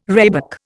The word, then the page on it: rhebok: Indian